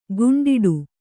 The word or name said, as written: ಕುಂಟಾಟ